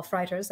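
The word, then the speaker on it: Frijters: his name is Paul Freiters. That's how you pronounce that.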